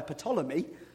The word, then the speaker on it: Ptolemy: Now, if you were the brother of a political pawn who had been pimped and poisoned by a Ptolemy, with a silent P, how might you feel?